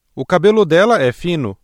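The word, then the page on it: fino: Brazilian Portuguese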